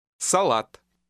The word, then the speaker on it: салат: Notice that the main stress is on the second A sound. – Салат.